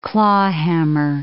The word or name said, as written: clawhammer